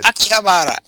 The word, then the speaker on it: Akihabara: Jonathan Hayes was over there, and he used to send us reports from Akihabara. Uh-oh. I could never pronounce it as well as you did. That's pretty good. – Akihabara.